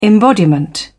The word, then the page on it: embodiment: British English